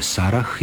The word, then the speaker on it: Sarah: U nominativu i hrvatsko ime Sara i strano ime koje se piše Sarah izgovaraju se jednako.